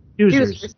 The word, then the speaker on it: Doozers: I remember that my favorite characters on Fraggle Rock were Dozers? How do you pronounce it? – Doozers.